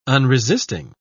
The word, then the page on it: unresisting: British English